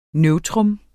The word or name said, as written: neutrum